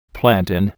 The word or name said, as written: plantain